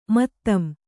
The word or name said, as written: ಮತ್ತಂ